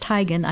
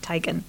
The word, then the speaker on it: Teigen: But and as an aside, his wife, Chrissy Teigen, and it is pronounced Teigen. I follow her on Instagram, and she revealed that that's how you pronounce it, Teigen.